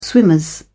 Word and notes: swimmers: British English

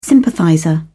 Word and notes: sympathizer: British English

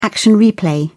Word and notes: action replay: British English

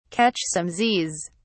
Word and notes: catch some z's: British English

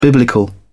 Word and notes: biblical: British English

